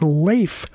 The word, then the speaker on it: Leif: And I did some looking, and in fact, there's an interview from 2010 with Leif Garrett on VH1 where he says, yep, I've always pronounced it Leif, the Norwegian pronunciation.